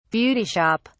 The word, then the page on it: beauty shop: British English